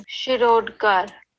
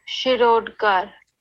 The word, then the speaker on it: Shirodkar: The following pronunciation is Shirodkar. – Oh. – Shirodkar. – Shirodkar. – That's way fancier than the way I would.